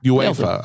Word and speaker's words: UEFA: UEFA.